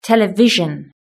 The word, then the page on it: television: British English